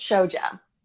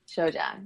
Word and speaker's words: Shoja: And how do you say your last name? – It's Shoja. – Shoja. – Shoja, yeah.